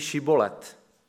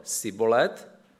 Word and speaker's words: Šibolet: Když odpověděl nejsem, vyzvali ho, tak řekni Šibolet. Když ale řekl Sibolet, protože neuměl vyslovit š, popadli ho a zamordovali.